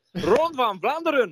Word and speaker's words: Ronde van Vlaanderen: Est-ce que je prononce bien Frédéric Moncassin Parce que j'ai pas forcément l'accent euh, adéquat. faut le prononcer comme un guerrier. – Non, il faut dire Ronde van Vlaanderen. – Voilà, eh ben, parfait. C'est beaucoup mieux.